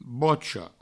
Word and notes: bocha: bocho

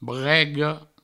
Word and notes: brega: brego

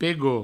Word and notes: pegot: provencal

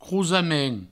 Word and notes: crosament: crousamen

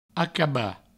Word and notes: acabar: acaba

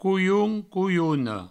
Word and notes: colhon: colhona; couioun; couiouno

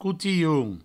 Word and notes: cotilhon: coutihoun

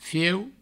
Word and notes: fieu: Provençal

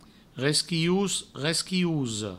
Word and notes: resquilhos, resquilhosa: resquihous, resquihouso; Provençal

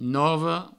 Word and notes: nova: novo

